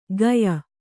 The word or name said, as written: ಗಯ